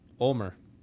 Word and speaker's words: Ulmer: Ulmer.